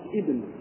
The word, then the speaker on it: ابن: إذا ابتدأت بها نطقت قلت ابن وإذا وصلت اسقطت